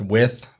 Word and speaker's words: Wythe: It's spelled out Wythe, but it is pronounced with like With.